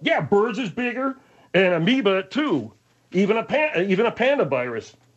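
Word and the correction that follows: Pandoravirus: It's actually, I mispronounced, it's a Pandora. P-A-N-D-O-R-A. Pandora virus. Pandora virus.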